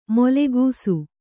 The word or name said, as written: ಮೂಲಗಿಸು